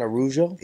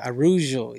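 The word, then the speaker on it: Araújo: Am I saying it right? – Viviane Araujo. – Yeah, Araujo. Yeah, you have her.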